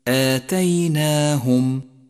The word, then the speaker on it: آتيناهم: آتيناهم هي كلمة مرققة بكامل حروفها، فكل حروفها مستفيلة، وهذا يستدعي منك نطقا صحيحا لها، فإياك والتفخيم في هذه المواضع.